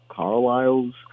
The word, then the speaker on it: Carlisle: Carlisle's C-A-R. Oh, where I lost it. Hold on. – It's C-A-R-L-I-S-L-E, Carlisle.